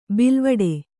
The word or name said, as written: ಬೇಲಿವೀಡು